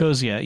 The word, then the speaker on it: Ecosia: Safari allows you to choose Google, Yahoo, Bing, DuckDuckGo, and Ecosia. I don't know if I'm pronouncing that one right. – Oh, but Ecosia. Yeah.